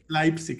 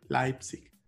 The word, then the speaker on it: Leipzig: En alemán es Leipzig. Es que es E-I, se pronuncia A-I, Leipzig.